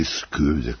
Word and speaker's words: Skövde: Toliko sekundi ima da je počeo meč između rukometašica Švedske i Srbije u švedskom gradu Skövde ili Skövde, kako ga oni izgovaraju.